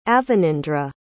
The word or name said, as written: avanindra